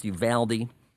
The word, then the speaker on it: Uvalde: Interestingly enough, this town, pronounced Uvalde, is another way it's, I think, more locally pronounced Uvalde, and it is the hometown of Matthew McConaughey, of all things.